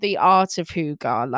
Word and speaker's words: hygge: My second top tip is to embrace the Scandinavian culture of coziness called hygge, which is not spelt like that. It's spelt H-Y-G-G-E, but it's apparently pronounced hygge, which is...